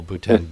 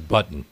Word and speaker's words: Bhutan: We hope that in the process he will learn to pronounce Nepal as Nepal, not as nipple, and not call Bhutan button.